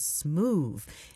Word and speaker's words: Smoovee: And before that, Vel Lewis and Marion Meadows teamed up to bring us Smooth. And you have to say it like that, because it's spelled S-M-O-O-V-E-E, and it certainly is.